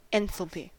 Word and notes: enthalpy: American English

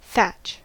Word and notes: thatch: American English

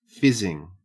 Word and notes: fizzing: Australian English

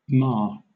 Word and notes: mar: British English

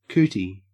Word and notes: cootie: Australian English

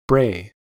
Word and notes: bray: American English

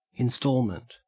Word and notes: instalment: Australian English

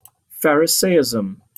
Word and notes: pharisaism: British English

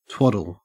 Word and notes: twaddle: Australian English

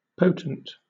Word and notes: potent: British English

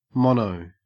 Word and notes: mono: Australian English

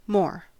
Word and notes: more: American English

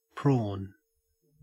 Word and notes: prawn: Australian English

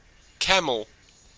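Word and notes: camel: au Australian English

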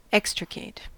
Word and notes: extricate: American English

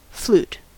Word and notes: flute: American English

